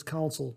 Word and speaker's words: Council: I was saying Council. I was emphasizing the S-U-L. My apologies, it's Council. So I have to remember to pronounce it that way.